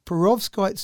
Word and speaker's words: perovskite: So what they've done is they've used, and help me if I've got this wrong, perovskite. How's that sound for pronunciation? – Sounds good to me. I've never used that word before. – Okay, good. – So we're going to go with perovskite.